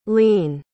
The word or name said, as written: leane